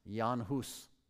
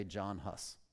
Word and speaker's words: Jan Hus: His writings greatly influenced the next guy, properly pronounced Jan Hus. We might say John Hus.